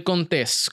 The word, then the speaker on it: Contés: Mi nombre es Miguel Contés con acento en la E y nos vemos en la próxima.